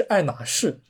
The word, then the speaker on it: 爱马仕: 但是如果你揪英文的原名称的话，其实应该是爱马仕啊，因为爱马仕它的这个 H 啊，它是不发音的啊。